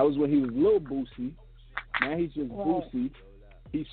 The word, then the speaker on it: Boosie: That's not how you say it? – Boosie. – Boosie. – Yes.